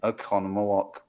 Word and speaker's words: Oconomowoc: Okay, so the first town where apparently the Wizard of Oz screened ever was the Strand Theater in Oconomowoc, Wisconsin. Did I say it right? – You did.